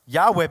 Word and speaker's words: Yahweh: Yahweh, but remember how you say it? It's like taking a breath, okay?